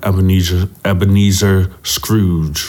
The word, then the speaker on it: Ebenezer Scrooge: I'm gonna sound like Ebenezer Scrooge.